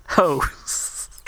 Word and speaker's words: Heaux: H-E-A-U-X. Hoes.